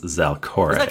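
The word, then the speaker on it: Zalcoré: That's Z-A-L-C-O-R and then an E with an accent over it. I've decided that it's pronounced Zalkore.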